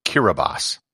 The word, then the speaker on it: Kiribati: The letters T-I form an S sound in the Gilbertese language. Hence, what should be Kiribati is pronounced Kiribati. However, it gets far better than just the name of the country.